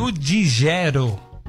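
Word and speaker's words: Ludgero: Como é que chama? – Ludigero. Ludigero. – Ludigero. É São Ludigero.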